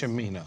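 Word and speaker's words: Cimino: If I say his name, it is Chimino, correct? Yes. Chimino.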